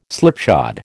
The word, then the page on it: slipshod: American English